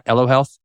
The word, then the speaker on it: Elo Health: And so whenever I learned about what you are doing at Elo Health, am I pronouncing that right? Elo Health? – Yep, that's great.